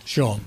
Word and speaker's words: Shaun: Sean. – That's a good name to have, Sean. It's spelled S-H-A-U-N, though.